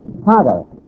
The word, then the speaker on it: Hagar: Hagar. This is Egyptian Arabic pronunciation of Hagar.